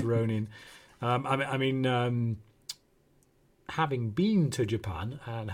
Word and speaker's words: Ronin: So they never drag letters out unless they double it up. So that would definitely be Ronin. That'd be more close to the pronunciation. It's Ronin.